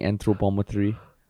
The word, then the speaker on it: anthropometry: Is that how do you pronounce it? Anthropometry? Yeah. – Anthropometry.